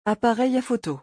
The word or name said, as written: appareil a photo